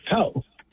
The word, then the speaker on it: Powhatan: How the hell do you pronounce this in Virginia? Powhatan? – Powhatan. Powhatan, like the Indians, Powhatan.